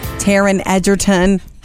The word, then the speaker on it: Taron Egerton: He was involved with Taron Egerton. Ha! Said it right.